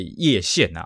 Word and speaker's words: 歙县: 那么那个这个歙县呢，又称为叶县呐，哦，啊、呃，就是应该念破音字要念成叶了，哦，就叶县呐，哦。